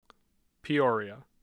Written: Peoria

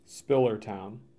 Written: Spillertown

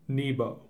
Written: Nebo

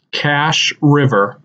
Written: Cache River

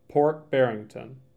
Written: Port Barrington

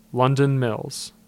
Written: London Mills

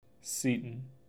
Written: Seaton

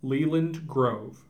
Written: Leland Grove

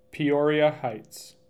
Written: Peoria Heights

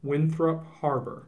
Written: Winthrop Harbor

